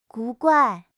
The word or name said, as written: 古怪